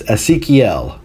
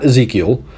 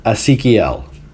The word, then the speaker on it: Ezequiel: It's Ezequiel is how you pronounce his first name, not Ezequiel. Ezequiel.